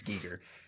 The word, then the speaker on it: Giger: And I don't remember how to read it anymore, but it's Giger.